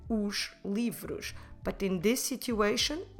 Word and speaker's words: os: and that will be os.